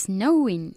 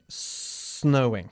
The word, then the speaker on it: snowing: It's snowing. – It's snowing, muy bien. Un verbo que empieza con el sonido de la S. Snowing. – Okay. – No se dice snowing, por supuesto.